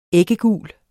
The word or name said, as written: æggegul